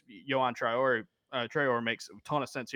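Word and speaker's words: Traoré: It's pronounced Traore.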